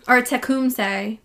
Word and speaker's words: Tecumseh: And then there was Tecumseh. – I think. – Yeah. You've learned about him in history class. Very, very like popular, well-known Native American. – That sounds familiar. – Tecumseh.